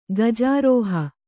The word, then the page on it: ಗಜಾರೋಹ: kannada